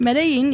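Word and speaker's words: Medellín: We had Medellin cartel. Uh-huh. I'm not going to probably pronounce this right. – Medellin, yes.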